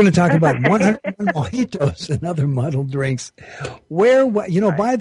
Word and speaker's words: mojitos: So the J sounds like an H, Mojitos.